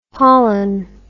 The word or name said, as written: pollin